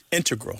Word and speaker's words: integral: Just to clarify here, both correct, but the mathematicians and math-using scientists are more likely to say integral with the stress on the first syllable.